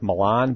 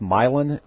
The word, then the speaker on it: Milan: It's spelled like Milan, but it's pronounced Milan.